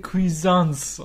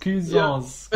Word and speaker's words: Cuisance: Ich glaube aber, es wird Michael Cuisance ausgesprochen. – Wahrscheinlich Cuisance. war das auch Cuisance, ja. genau.